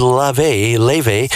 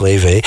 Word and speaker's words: Laufey: She's got together, this is Leve. I think that's how it's pronounced. It's spelled Laufey, but I think it's pronounced Levy.